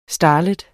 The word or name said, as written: starlet